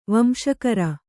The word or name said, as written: ವಂಶಕರ